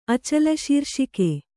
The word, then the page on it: ಅಚಲಶೀರ್ಷಿಕೆ: Kannada